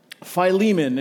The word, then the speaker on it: Philemon: It's a letter from the Apostle Paul to Philemon. And yes, that's how you pronounce it. We talked about this in community group this week. There are lots of ways you may have thought. I'm just going to encourage you, that's how it is.